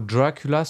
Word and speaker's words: Dracula: Par exemple, le mot Dracula se prononce en accentuant dra.